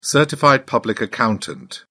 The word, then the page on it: certified public accountant: British English